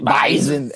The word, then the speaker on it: Bison: How do you pronounce it? It's a Z. – It's like a Z. – Bison.